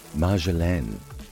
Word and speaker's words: marjolaine: Marjoram in French is pronounced marjolaine and spelled M-A-R-J-O-L-A-I-N-E.